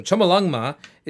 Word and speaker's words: Chomolungma: So Chomolungma is the Tibetan pronunciation, which means Mother Goddess of the Universe.